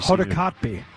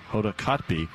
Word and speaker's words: Hoda Kotb: Hoda Kotb. – Hoda Kotb.